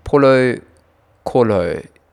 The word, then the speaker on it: Paulo Coelho: So Paul, I actually don't know how to say this guy's name, Paulo Coelho, P-A-U-L-O-C-O-E-L-H-O, Paulo Coelho, from The Alchemist.